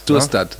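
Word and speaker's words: Storstad: Schorstadt, spricht man? Spricht Sturstadt. Ne? – Sturstadt.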